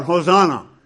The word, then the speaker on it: Hosanna: They shout, Hosanna. That's the pronunciation from the Hebrew, Hoshi'ana, which means give salvation now, or give victory now, in a martial context.